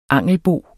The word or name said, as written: angelbo